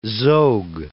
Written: Zog I

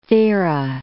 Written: Thera

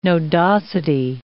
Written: nodosity

